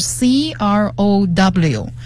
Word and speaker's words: crow: 它拼写是 C-R-O-W，它的发音是 crow， crow 是乌鸦的意思。